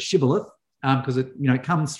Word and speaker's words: shibboleth: And the reason they set that as the password is that they knew that the other tribe pronounced it differently. They pronounced it as shibboleths.